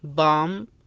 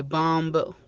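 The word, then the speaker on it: lamb: بالنسبة لحرف البي ما تكلمنا عنه، فإنه يكون بعض الأحيان يكون صامت، السبب إذا أتى مباشرة قبل حرف إم، مثل كلمة بام، كام، أو كلايم. ما تنطق كلايمب ولا تنطق الكلمة الأولى اللي هي بامب ولا كامب، تنطق بحرف سايلنت البي.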